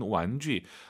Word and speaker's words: 玩具: 제 유 발음 잘하세요? 주 아니고 쥐입니다, 여러분. 완쥐.